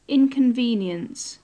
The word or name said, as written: inconvenience